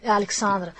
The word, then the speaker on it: Alexandra: Da un lato Serena, che è l'organizzatrice della festa italiana, e dall'altra invece Alessandra, che di professione è anche Alexandra, scusami, è vero la pronuncia alla tedesca, che di professione è anche DJ.